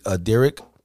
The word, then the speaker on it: Dyryk: I'm saying his name correctly. Derek. D-Y-R-Y-K.